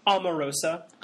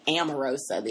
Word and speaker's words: Omarosa: How do you pronounce her name? – Omarosa. – Omarosa. Yes, I heard people pronouncing it Amarosa the other day.